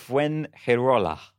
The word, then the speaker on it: Fuengirola: who apparently lived and died in Fingerola. Have I said that correctly? – No, I don't think so, but... – How'd you say it? – Fuengirola.